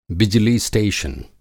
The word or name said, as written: बिजली स्टेशन